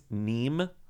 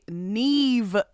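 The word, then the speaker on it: Niamh: It's N-I-A-M-H. I think it's Neem de Bruin. – No, that's incorrect. It's actually Neve de Bruin. – It's Neve. And then we have the next person is, I believe, I'm going to say Huey Mockin.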